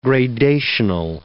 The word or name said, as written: gradational